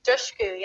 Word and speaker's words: Dushku: Am I saying your name correctly? – How are you saying my name? – Eliza Dushku? – Dushku, yeah.